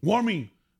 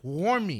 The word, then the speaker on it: Wuarmi: Warmy. – W-U-A-R-M-I. Warmy. – Oh, W-U. – Yeah. – Warmy. – Sí, es que saber hacer las cosas o saber cocinar bien. Fabián Calle, Warmy es muchacha, femenino, guambra.